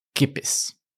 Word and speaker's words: Kippis: And so that is, am I saying this right? Kippis? – Kippis. Yes.